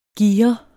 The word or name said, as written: gire